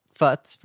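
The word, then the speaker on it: Pfutz: That's P-F-U-T-Z. How would you say that? Futz? – Futz, probably, yeah. It's good, Futz. Though. I like it. – Yeah, I like it. And Mabel Wong. Wow. Yeah. – Everyone. The P is silent.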